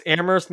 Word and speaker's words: Amherst: It's Amherst, isn't it? I think that's one of those cities where you don't pronounce the H. I think it's a silent H.